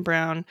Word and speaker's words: Braun: We've also talked about Bruce Brown, Christian Brown, which his name is spelled Brown.